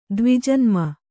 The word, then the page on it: ದ್ವಿಜನ್ಮ: kannada